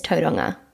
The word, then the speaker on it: Tauranga: Oh yeah, and by the way, it's called Tauranga, or the right pronunciation is Tauranga, from where I was brought up.